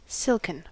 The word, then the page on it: silken: American English